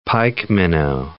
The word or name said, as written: pikeminnow